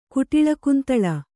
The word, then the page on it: ಕುಟಿಲಕುಂತಲ: kuṭila kuntala